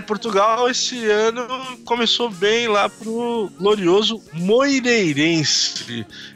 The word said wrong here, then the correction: Moreirense: É, é, você tem que até dar uma pausa para pronunciar o, o, nome do Moireirense para no Moreirense, sabe? No, no, no engolir alguma letra.